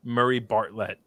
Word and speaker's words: Murray Bartlett: What was it? – Murray Bartlett. That's how you say his name. – Murray Bartlett. All right.